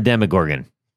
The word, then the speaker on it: Demogorgon: Yeah, and the Demogorgon.